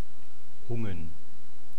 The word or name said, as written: hungen